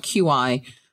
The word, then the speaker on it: Qi: It's spelled QI, but it's pronounced Qi chargers.